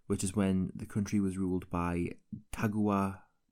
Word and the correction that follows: Tokugawa: Was ruled by the Tokugawa shogunate from 1603 to 1868.